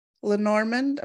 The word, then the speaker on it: Lenormand: Jean teaches students from all around the world, and she teaches them the art of divination using runes, tarot, Lenormand, am I saying that right? – Yes.